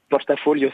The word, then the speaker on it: portafolio: Donc si on veut se régaler avec cette pizza napolitaine pliée portafolio, c'est ça, je le dis bien portafolio, c'est très bien.